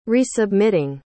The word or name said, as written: resubmitting